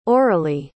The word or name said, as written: oralie